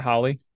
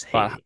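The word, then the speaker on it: Halle: Like, it's not Holly Berry. It's Holly Bailey. – And nope. That's what I said too. My little sister corrected me. It's Haley. I said, her wiki literally says not to be confused, Holly Berry. Fair. – It's Haley. I think it's supposed to rhyme with Chloe.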